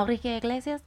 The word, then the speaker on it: Enrique Iglesias: Enrique Iglesias, did I say that right? Yeah. Enrique Iglesias.